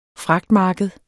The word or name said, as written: fragtmarked